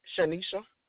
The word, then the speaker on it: Shanisha: Shanisha. – Spell that for me, please. – S-H-A-N-I-S-H-A. – And your last name? – T-O-H-I-L-L.